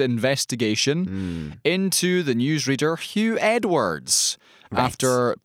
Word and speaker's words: Huw: No, it's Hugh is how you say it.